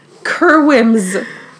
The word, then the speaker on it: Kerwims: K-E-R-W-I-M as in mother, S. Kerwims. And then, but in Dominican, you pronounce it Kewing.